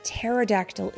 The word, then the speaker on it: pterodactyl: And I want to say really quick before we get started, this is a tricky word because it has a hidden letter. The P. Mm-hmm. The word pterodactyl is spelled P-T-E-R-O-D-A-C-T-Y-L. P-T-E-R-O-D-A-C-T-Y-L. Pterodactyl. But it has a P in front. You just don't say the word.